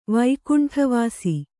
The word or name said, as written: ವೈಕುಂಠವಾಸಿ